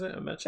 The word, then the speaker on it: Ameche: So you got the Duke brothers, Ralph Bellamy and Don Ameche. How do you say it? Ameche. – Don Ameche, yeah.